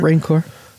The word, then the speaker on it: Rancor: Or even when that Rancor, is that how you say it? Rancor? – Oh, Rancor? – Yeah, Rancor.